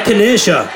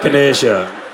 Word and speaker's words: Echinacea: I'm going for I need a middle class person to tell me how to pronounce this. An elderberry and Echinacea. Is that right? – Echinacea. – Oh, so thank you. – Echinacea.